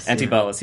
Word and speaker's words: Antibalas: worked with Antibalas. I don't know if I'm pronouncing it right. – Antibalas, yeah, there you go.